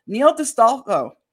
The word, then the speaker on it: Neil D'Astolfo: So please help me welcome the incredible Neil DeStolfo.